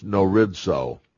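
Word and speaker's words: gnorizo: A third word that you need to be familiar with is norizo. Norizo. The initial G is silent, like in Gnostic and words like that.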